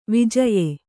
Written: ವಿಜಯ

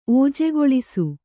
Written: ಓಜೆಗೊಳಿಸು

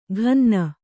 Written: ಘನ್ನ

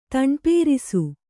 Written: ತಣ್ಪೇರಿಸು

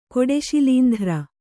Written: ಕೊಡೆಶಿಲೀಂಧ್ರ

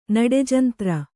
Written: ನಡೆಜಂತ್ರ

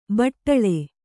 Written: ಬಾಟಲಿ

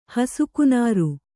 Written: ಹಸುಕುನಾರು

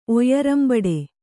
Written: ಒಯ್ಯರಂಬಡೆ

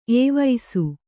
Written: ಏವಿಸು

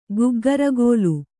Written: ಗಗ್ಗರಿಗೋಲು

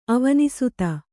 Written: ಅವನಿಸುತೆ

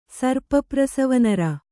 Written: ಸರ್ಪಪ್ರಸವನರ

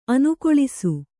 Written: ಅನುಕಲಿಸು

